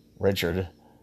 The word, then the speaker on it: Richard: Well, it was hysterical in part because Keith, you know, I don't call it Keith, I call him Keith Richard. I pronounce it the way Mick Jagger does.